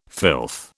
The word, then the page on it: filth: British English